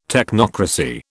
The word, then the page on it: technocracy: British English